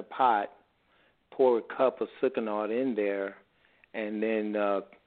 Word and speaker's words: Sucanat: Sucanat.